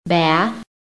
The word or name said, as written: BATH